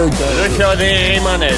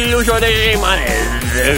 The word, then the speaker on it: Lucio de Rimanez: Lucio de Rimanez. – There we go. Lucio de Rimanez.